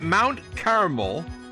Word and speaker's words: Carmel: So for clarification, remember, folks, it's Mount Carmel, two syllables, and if you use three syllables, you're talking about candy. And if you use two syllables, you're talking about a holy mountain in the Holy Land.